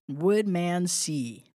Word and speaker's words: Woodmansey: And it's spelled exactly the way it looks exactly the way I'm saying it. Woodmansey.